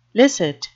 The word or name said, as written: licit